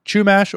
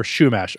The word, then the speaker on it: Chumash: originally, the Los Angeles area was home to the Chumash or Chumash. I looked up different ways to pronounce it, and those were the two that I saw.